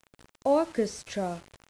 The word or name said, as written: orchestra